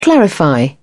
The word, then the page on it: clarify: British English